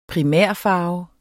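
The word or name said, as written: primærfarve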